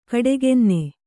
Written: ಗೋಡೆಗನ್ನ

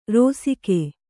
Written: ರೋಸಿಕೆ

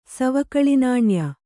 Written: ಸವಕಳಿನಾಣ್ಯ

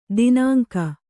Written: ದಿನಾಂಕ

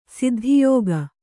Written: ಸಿದ್ಧಿಯೋಗ

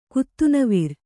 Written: ಕುತ್ತುನವಿರ್

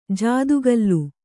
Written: ಜಾದುಕಲ್

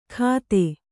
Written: ಕತ್ತೆ

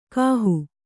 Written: ಗಾಹು

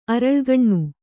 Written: ಅರಳುಗಣ್ಣು

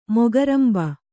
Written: ಮೊಕರಂಬೆ

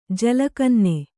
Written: ಜಲಕನ್ನೆ

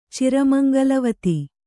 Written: ಚಿರಮಂಗಲವತಿ

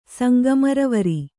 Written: ಸಂಗಮರವರಿ

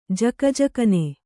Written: ಝಗಝಗನೆ

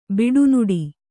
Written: ಬಡನುಡಿ

